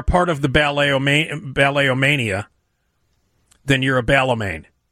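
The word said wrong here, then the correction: balletomane: Balletomain. – Balletomain? They actually pronounce... – Balletomain. – Wait, one more time? – Balletomain. – Well, they're actually pronouncing the T.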